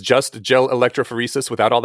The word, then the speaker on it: electrophoresis: You had this interesting thing, lab work protocols, where you gave a bad example is a protocol of gel electrophoresis.